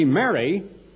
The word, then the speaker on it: Mary: Well, to be Mary, M-E-R-R-Y, is to be happy, isn't it? But believe it or not, this is more of a long A and should be more like Mary.